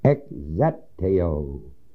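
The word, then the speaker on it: ekzeteo (ἐκζητέω): That word, or those words, seeketh after, is the Greek word ekze, long e, t-e-o, long o, ekzeteo.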